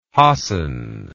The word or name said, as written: hassan